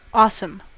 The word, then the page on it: awesome: American English